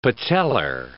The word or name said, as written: patellar